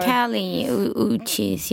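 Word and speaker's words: Kali Uchis: Is that how I pronounced it right? – We've also played her before. Callie Uchis, yeah.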